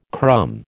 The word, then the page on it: crumb: American English